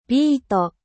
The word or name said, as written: betterave